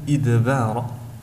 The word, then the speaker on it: إدبار: كذلك في حرف الدال في كلمة إدبار، حرف الدال هنا جاء مقلقلا ولكنه مستفل، فينطق إدبار،